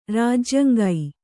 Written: ರಾಜ್ಯಂಗೈ

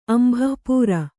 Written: ಅಂಭಃಪೂರ

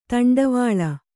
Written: ದಂಡವಳ

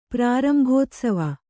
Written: ಪ್ರಾರಂಭೋತ್ಸವ

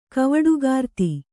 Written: ಕವಡುಗಾರ್ತಿ

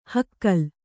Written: ಹುಗಿಲು

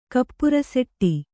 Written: ಕಪ್ಪುರಸೆಟ್ಟಿ

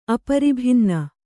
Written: ಅಪರಿಭಿನ್ನ

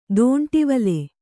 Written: ದಂಡವಳ

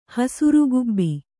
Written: ಹಸುರುಗುಬ್ಬಿ

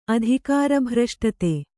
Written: ಅಧಿಕಾರಭ್ರಷ್ಟತೆ

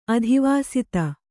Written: ಅಧಿವಾಸಿತ

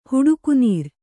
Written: ಹಡಿಕುನಾರು

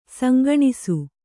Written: ಸಂಗಣಿಸು

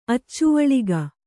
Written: ಅಚ್ಚುವಳಿಗ

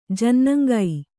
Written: ಜನ್ನಂಗೈ

